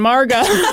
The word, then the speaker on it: Margot: I was like, nope, it's French. Don't pronounce the T on Margot.